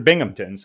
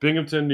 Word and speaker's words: Binghamton: I mean, you butchered Binghamton, so. – Binghamton? Oh, I did. Binghamton, New York. – You said Binghamton. – Binghamton, sorry.